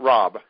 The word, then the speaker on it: Raab: I mean, I'm John Robb, so very easy. Except my last name is spelled R-A-A-B, so people call me Rab, and I answer to anything.